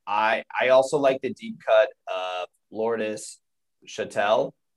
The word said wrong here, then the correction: Chantel: Is that, I think I'm saying that right? – Chantel, yeah.